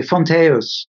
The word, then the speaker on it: Fonteus: I say it with a Scandinavian accent, probably. Fonteus. – Fonteus. Beautiful. Yeah. F-O-N-T-A-E-U-S.